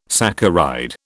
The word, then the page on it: saccharide: British English